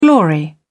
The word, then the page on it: glory: British English